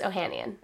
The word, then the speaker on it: Ohanian: Is it Ohanian? I've been calling him Ohanian this entire time. – It's like Ohanian. Is it however you pronounce Armenian? – fair. – But I think he usually says Ohanian. – Okay. – That's the American pronunciation.